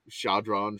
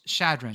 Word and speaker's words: Chadron: Bean soup, not hot dogs, will be the featured snack at the Thanksgiving football game here Thursday between Idaho State and whatever Chadron. Well, I'm saying it wrong. – It's Chadron.